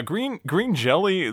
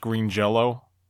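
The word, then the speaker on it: Green Jellÿ: Yeah, green jelly, they were actually pronounced Green Jello. They put an umlaut in their name, so it looked like jelly, but they always claimed it was supposed to be pronounced Jello.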